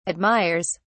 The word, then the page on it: admires: British English